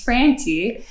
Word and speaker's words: Frantti: And then I said, okay, my last name's Frantti. Uh-huh. But she couldn't pronounce the fra so well.